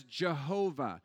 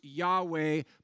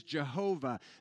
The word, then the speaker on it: Jehovah: It can also be pronounced Yahweh because the Hebrew is just four consonants. So depending on what vowels you slide in between those consonants, it's pronounced differently. The Latin pronunciation is Jehovah.